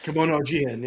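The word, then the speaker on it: Kemono Jihen: Kimono Jihen, yeah.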